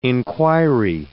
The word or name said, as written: inquiry